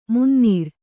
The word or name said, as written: ಮುನ್ನೀರು